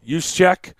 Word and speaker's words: Juszczyk: By the way, 44, how do you say his last name? – Juszczyk.